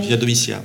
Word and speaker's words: Via Domitia: Via Domitia Olivette au Crès, j'espère que je ne... fais pas d'erreur de prononciation. – Via Domitia, mais oui.